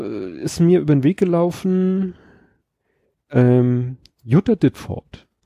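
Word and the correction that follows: Ditfurth: Furt. Furt. Dit Furt. Die sagt dir was. Die darfst du ne? Deutsch aussprechen. Das ist kein englisches Wort.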